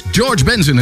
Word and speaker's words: George Benson: Oké, okay, Cimbello, hey, hier is George Benson. En, zeg ik het goed? Ja, George Benson.